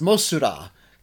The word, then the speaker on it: Mosura: In fact, it's pronounced Mosura. Because there's no TH sound in Japanese.